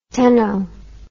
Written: cteno

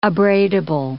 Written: abradable

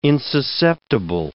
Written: insusceptible

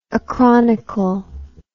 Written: acronically

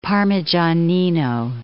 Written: Parmigianino

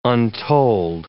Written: untold

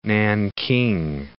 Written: Nanking

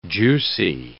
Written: juicy

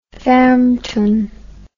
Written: farmtoun

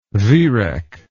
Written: viereck

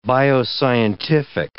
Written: bioscientific

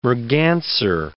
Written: merganser

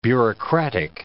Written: bureaucratic

